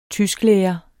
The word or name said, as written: tysklærer